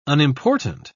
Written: unimportant

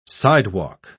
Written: sidewalk

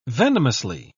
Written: venomously

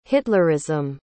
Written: hitlerism

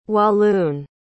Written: walloon

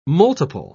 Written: multiple

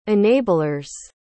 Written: enablers

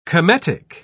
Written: cometic

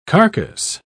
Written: carcass

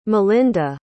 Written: malinda

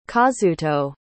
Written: kazuto

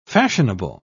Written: fashionable

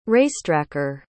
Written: racetracker

